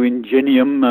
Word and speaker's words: Ingenium: It, is that the new Ingenuine model? – Yes, if that's how it's pronounced, the new Ingenium.